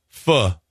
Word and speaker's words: pho: You can order candy canes that taste like ketchup, pizza, or what is PHO? How do you say that? – Fuh. – Fuh.